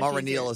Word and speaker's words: Mareneil: Well, the way my mom spells it, I'm Haitian, so my mom spells it M-A-R-E-N-E-I-L. – Okay. – So in school, it's Maraneel. – Yeah, Maraneel is kind of how you phonetically say it.